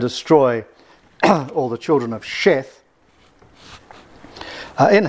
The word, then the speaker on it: Sheth: and if one points it with a dot on the right side, as it is here, this Hebrew letter is called Shin, and pronounced Sh, and hence Sheth.